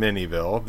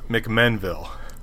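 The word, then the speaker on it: McMinnville: First it was Mike from Minneville, then Mike from McMinneville, then back to Minneville again. It's pronounced McMenville.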